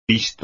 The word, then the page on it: pisto: espagnol